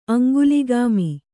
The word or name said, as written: ಅಂಗುಲಿಗಾಮಿ